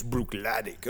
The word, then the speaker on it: Bruichladdich: And you're just waiting for that patron at the bar to say, well, actually, it's Bruichladdich.